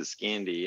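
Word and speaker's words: Scandy: But there have been so many, like, I would say prior to Polycam, the big one was Scandi or S Candy. Mm-hmm. I don't know what the hell people call it, to be honest. – I pronounce it Scandi.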